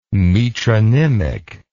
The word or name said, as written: metronymic